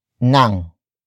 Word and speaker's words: ng: And then we have nang. Nang. Nang. Nang is spelled as ng.